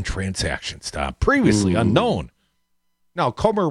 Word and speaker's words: Comer: Comer rhymes with Gomer